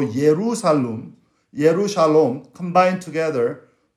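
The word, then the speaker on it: Yerusalem (ירושלים): Yeru, actually in Hebrew, pronounces a Yeru. Yerusalem. Shalem. Shalem.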